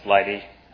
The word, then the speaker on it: lighttpd: So basically, someone had an open source project, and that project was called LIGHTD, L-I-G-H-T-T-P-D, pronounced LIGHTD.